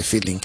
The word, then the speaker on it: filin: Tres o cuatro temas de feeling. Y feeling, claro, y se escribe tal cual, F-I-L-I-N, feeling, tal cual como se pronuncia una palabra anglosajona, feeling, que significa sentimiento.